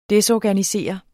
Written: DESORGANISERE

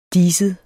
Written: diset

